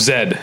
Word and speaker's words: Z: Of Zed is how I like to say it. – Yeah. – That's how they say it in the movie. Right? – Yeah, but he has said Z, so I'm okay with that.